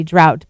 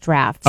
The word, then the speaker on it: draught: So here, D-R-A-U-G-H-T would be drought, but there it's pronounced draft.